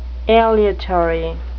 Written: aleatory

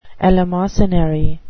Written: eleemosynary

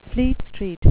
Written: Fleet Street

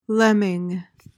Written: lemming

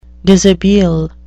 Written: dishabille

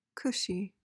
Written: cushy

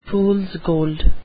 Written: fools gold

